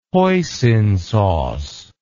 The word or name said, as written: hoisin sauce